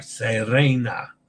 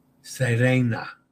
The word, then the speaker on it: Serena: Roll the R. Serena. I know. E is pronounced A as in day, like in Venezia. Serena.